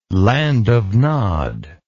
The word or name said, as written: land of Nod